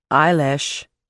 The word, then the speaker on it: Eilish: Eilish. – She pronounced the name strangely, with too much emphasis on the second syllable.